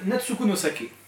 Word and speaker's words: Natsuko no Sake: Il s'agit de Natsuku no Sake, édité par Vega, écrit et dessiné par Akira Ose.